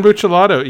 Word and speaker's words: Buccellato: And even though he didn't finish the original series, that was I believe his name is pronounced Brian Buccellato, yes, who wrote one of my favorite Flash runs with Francis Manapul.